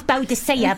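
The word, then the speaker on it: Boadicea: said that, went, hello, I'm Boudica. Actually, it's Boudicea, but nobody pronounces it right.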